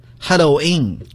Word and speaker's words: Halloween: 第一个生词 ,Halloween。 – Halloween。 – Halloween, Halloween、 yeah. 其实有好多人他的发音不一样，其实一样的，你说 Halloween, 这是美国很普遍的一种民间的发音方式，但是在官方呀，官方就把它界定为 Halloween, 其实读作 Halloween, Halloween 都是可以的。